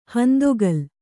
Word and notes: ಹಂದೊಗಲ್: handoga

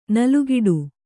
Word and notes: ನಲುಗಿಡು: kannada; nalugiḍu

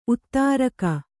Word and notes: ಉತ್ತರಿಗೆ: uttarige

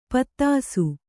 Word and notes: ಪೊದೆಸು: podesu; Kannada

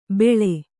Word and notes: ಬೇಳೆ: bēḷe; Kannada

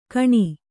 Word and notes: ಖಣ: Kannada

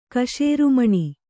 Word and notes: ಕಶೇರುಮಣಿ: kaśerumaṇi

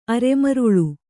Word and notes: ಅರೆಮರಳು: araemaraḷu

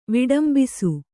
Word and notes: ವಿಡಂಬಿಸು: viḍambisu

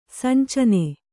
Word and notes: ಸಂಚನೆ: sancane; Kannada

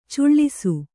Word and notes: ಚಾಳೈಸು: cāḷaisu

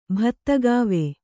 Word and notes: ಬತ್ತಿಕೋವಿ: Kannada

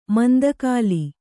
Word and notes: ಮಂತುಗೋಲು: mantugōlu

